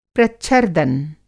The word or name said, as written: प्रच्छर्दन